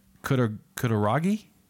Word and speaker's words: Kutaragi: According to former CEO Ken Kutaragi, did I say that right, John? – Mm-hmm.